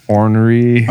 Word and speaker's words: ornery: You can pronounce onery on him. – Oh, ornery. – Onery. – Instead of ornery.